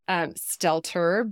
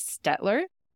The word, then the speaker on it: Stelter: On Instagram, I am Editor Stet, because people, you pronounced my name right, which was amazing. Thank you so much. Stelter, but for years when I was growing up, people thought our name was Stetler.